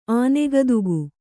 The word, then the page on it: ಆನೆಗದುಗ: Kannada